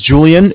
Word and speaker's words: Julian: And my name's Julian. It's J-U-L-I-A-N.